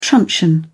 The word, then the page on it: truncheon: British English